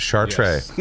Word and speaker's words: Chartrey: And that's how you pronounce it, Chartrey.